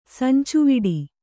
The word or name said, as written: ಸಂಚುವಿಡಿ